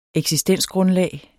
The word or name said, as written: eksistensgrundlag